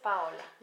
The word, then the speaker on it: Paola: Yes, Paola. – Yeah. And thank you for talking to me. Can you present yourself to the listeners? – Yes. My name is Paola Nieto.